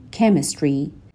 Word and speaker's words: chemistry: It changes their brain chemistry. خب این کلمه یه chemistry اصلاً صدای چه نداره.